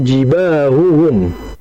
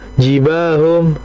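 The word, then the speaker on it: jibahuhum (جباههم): Jadi ada dalam Al-Quran itu, tepatnya di surah At-Taubah ayat 35, di mana Allah Subhanahu Wa Taala berfirman di sana itu jibahuhum, jibahuhum. Berarti ada dua ha ya di situ ya. Nah kata beliau Sofi, bimana al-khalis, yaitu untuk dijelaskan. Maka jangan kemudian kita bacanya jibahum, jibahum, jibahum, jibahum, jibahum. Jangan.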